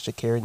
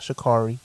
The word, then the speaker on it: Sha'Carri: I've been saying her name wrong this whole time, but her name is Sha'Carri, not Sha'Carri.